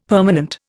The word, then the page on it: permanent: Indian